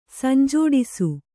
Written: ಸಂಜೋಡಿಸು

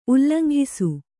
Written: ಉಲ್ಲಂಘಿಸು

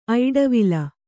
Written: ಐಡವಿಲ

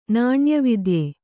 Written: ನಾಣ್ಯವಿದ್ಯೆ

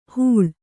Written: ಹೂಳ್

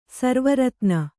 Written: ಸರ್ವರತ್ನ

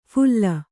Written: ಫುಲ್ಲ

